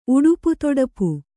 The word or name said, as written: ಉಡುಪುತೊಡಪು